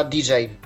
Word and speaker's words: djay: Mac OS X to jest aplikacja, która się nazywa DJ, po prostu. Pisze się DJAY dla jasności, a nie po prostu DJ ze skrótu, tak jak disk jockey, no ale do rzeczy.